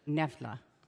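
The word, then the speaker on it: Knoephla: Nefla. – That's how you say it. – Yeah. – Hello, little Nefla. – The K is silent like everything else.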